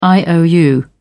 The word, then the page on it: iou: British English